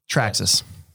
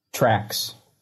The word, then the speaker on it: Traxxas: Let's try to think up all the different mispronunciations that people mispronounce when we're talking about RC companies. You ready? Traxxas. – Trax. – T-Rex. Or T-Rax.